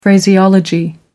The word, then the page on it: phraseology: American English